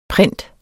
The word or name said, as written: print